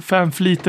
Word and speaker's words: Vanvliet: Han, på, han, han har ju sagt att eh, Fanfleet eller Vanvliet har eh, nederländskt påbrå och eh, i Nederländerna blir det Fanfleet enligt Skölden.